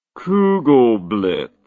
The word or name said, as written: kugelblitz